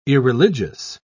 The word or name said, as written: irreligious